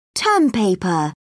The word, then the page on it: term paper: British English